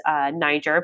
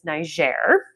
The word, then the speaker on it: Niger: So in Niger, which is the proper way to pronounce the country, I thought it was Niger, but it's Niger.